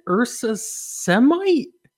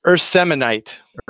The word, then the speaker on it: Urseminite: Is it Ursa Semite? – It's Urseminite. – Urseminite.